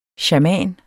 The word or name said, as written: shaman